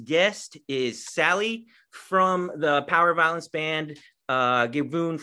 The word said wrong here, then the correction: Gewoon: No, they have the softer G, so everything is gewoon lachen en graf.